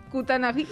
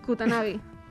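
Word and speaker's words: Kutaragi: Pero un tal Ken Kutanagami... No, si lo he dicho bien. Kutanagi. Yo puedo. – Uy, como lo digas mal, que soy Sonyer. – Kutanagi.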